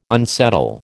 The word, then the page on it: unsettle: American English